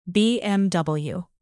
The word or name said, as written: bmw